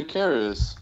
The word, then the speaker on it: Karras: Oh, Father Karras.